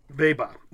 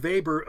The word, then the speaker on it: Weber: Weber. Don't pronounce the R at the end too well. – So Weber? – Yeah, but see, again, I would say Max Weber.